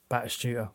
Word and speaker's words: Batistuta: Baptista. – Yeah, I've got Batistuta here. – Batistuta. Sorry, mate. Batistuta, that's what I meant. – Batistuta. – It was a mispronunciation, but you could have waited for me to give you the options.